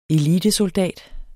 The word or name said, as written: elitesoldat